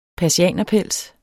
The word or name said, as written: persianerpels